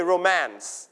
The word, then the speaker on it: romance: When two people love each other very much, นะครับผม we call romantic or romance, นะครับ romance or romantic ได้ทั้งสองนะครับผม okay repeat after me romance you stress on the second syllable นะครับเราจะไม่ออกเสียงพยางค์และไม่เน้นพยางค์แรกนะครับเน้นพยางค์ที่สองอ่านว่า romance ไม่อ่าน romance อันนั้นจะแปลว่าคนโรมันนะครับอ่านว่า romance นะครับ romance or romantic นะครับ talks about love stories Fall in love, sweet, heartwarming, and beauty of love.